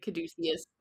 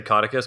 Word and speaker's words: Caudicus: I definitely pronounced that name wrong. I said like Caduceus. – That's okay. – I, I, I always say Caduceus in my head, and I'm gonna do my best to say Caudicus because that's how it's spelled.